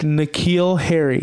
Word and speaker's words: N'Keal Harry: N'Keal Harry. – There you go. That was easy. How easy that was. That was so easy. – I slowed it down. Look, Evel Knievel, N'Keal Harry, my guy.